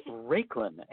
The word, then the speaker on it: Raiklen: It's Raiklen, and you did say it right, and thank you.